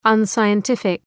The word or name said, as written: unscientific